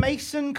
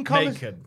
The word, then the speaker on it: Macon: The Macon, Mason, Macon. – Macon. – M-A-C-O-N. – But yeah, as if like just it looks like it rhymes with Macon.